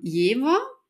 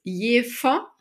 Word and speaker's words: Jever: Ja, wenn man so Touri hier ist, dann kann man das so aussprechen, wie es in der Werbung heißt. Ähm, Jever mit ähm, einem W gesprochen. Und sobald man aber hierher zieht und sich ähm, einleben möchte und sich integrieren möchte, dann ist das Erste, was einem an den Kopf geknallt wird auf eine ähm, norddeutsche Art, dass das Jever heißt ähm, und dass das in der Werbung immer falsch ausgesprochen wird.